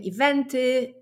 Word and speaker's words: event: W angielskim słówku jest akcentowana druga sylaba. Event. Don. Pu. Ok? Event. Więc jest to inaczej niż w języku polskim.